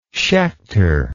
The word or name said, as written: schechter